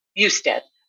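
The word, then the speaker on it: Bustad: Is it pronounced Busted? – Busted.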